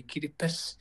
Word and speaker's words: Kiribati: Oh, it's just we don't have the S in our language, so we say Kiribati.